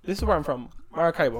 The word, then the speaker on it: Maracaibo: Well, it's Maracaibo. But I'm just kind of giving it the white American accent so you're, like... So, you understand.